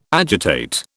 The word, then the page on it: agitate: British English